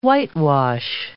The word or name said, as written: whitewash